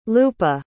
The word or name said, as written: lupa